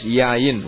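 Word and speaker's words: yayin (יין): You see, in Hebrew, the word for wine is yayin. It's pronounced yayin. Y-A-Y-I-N.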